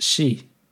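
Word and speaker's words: chi (四): och tydligen så är ju eh, det japanska ordet fyra eh, uttalas chi, vilket eh, har ett liknande uttal som ordet för död.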